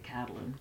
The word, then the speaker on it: Catelyn: It's actually spelled Caitlyn, but they pronounce it Catelyn.